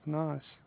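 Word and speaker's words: Naz: My name is Nas.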